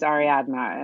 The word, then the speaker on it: Ariadna: That's because my actual name is Ariadna, and it's Ariadna. – Hard because that D at the end is not a natural pronunciation.